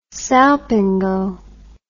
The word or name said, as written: salpingo